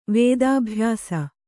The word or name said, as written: ವೇದಾಭ್ಯಾಸ